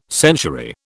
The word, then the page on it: century: British English